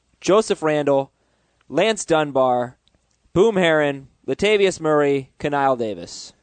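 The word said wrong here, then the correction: Knile: It's pronounced Nile Davis.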